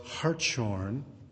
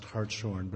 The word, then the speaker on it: Hartshorne: The great theologian that he mentions, Charles Hartshorne, or I should say Hartshorne, it's spelled like it should be pronounced Hartshorne, but it's Hartshorne, was an active member of our congregation in Austin, Texas.